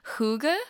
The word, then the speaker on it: hygge: You talk about, I'm not going to pronounce this right, but you talk about the Danish concept of hygge. – Perfect. – Really? Okay. So that's spelled H-Y-G-G-E.